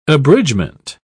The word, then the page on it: abridgement: British English